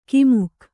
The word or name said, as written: ಕುಮಕ್ಕು